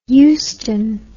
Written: euston

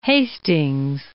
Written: Hastings

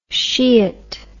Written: sheeit

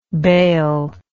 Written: baleless

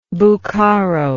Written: buccaro

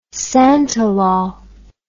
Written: santalol

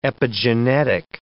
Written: epigenetic

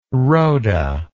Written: rhoda

